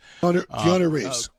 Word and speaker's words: Keanu Reeves: I think of Kenal Reeves, the Matrix guy. Kenal Reeves, right? Am I saying his name right? Kenal Reeves? – Keanu Reeves. – Keanu. That's the way you say Keanu.